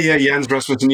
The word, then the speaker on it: Jens Rasmussen: And you mentioned Jens Rasmussen's, I'm not sure if I pronounced that correctly. – Yeah, Jens Rasmussen, yes.